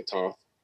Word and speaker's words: Thoth: And so if you look at Africa, you have T-H-O-T-H. Some people say Toth, depending on how you want to pronounce it.